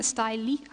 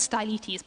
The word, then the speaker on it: Simeon Stylites: One of the most famous was a guy, Simeon Stylites. I don't know how you pronounce this. I always said Stylites, but apparently Stylites, who lived in the 4th and 5th century.